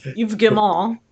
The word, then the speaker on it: Yves Guillemot: They did, I never know how to pronounce his name, but the company head, Yves Guillemot. Yves Guillemot, yes.